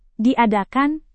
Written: diadakan

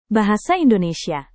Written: bahasa indonesia

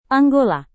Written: Angola

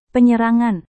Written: penyerangan

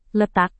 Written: letak

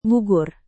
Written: gugur

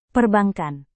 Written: perbankan

